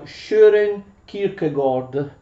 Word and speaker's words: Søren Kierkegaard: mi dicono quelli che se ne intendono che quelle due A si leggono come una specie di O e insomma più o meno in danese il nome del nostro filosofo si pronuncia Søren Kierkegaard.